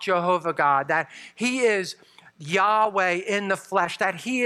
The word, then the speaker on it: Yahweh: And so some have added a J sound and come up with Jehovah. Most scholars believe it's pronounced Yahweh.